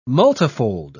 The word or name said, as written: multifold